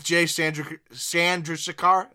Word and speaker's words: Chandrasekhar: With Jay Chandrasekhar, is that how you say his name? – Yeah.